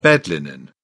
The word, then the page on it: bed linen: British English